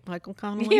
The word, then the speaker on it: Michael Connelly: Um, from Michael Connelly. Mm-hmm. I got the name right this time, right? Michael Connelly. – You did, yeah.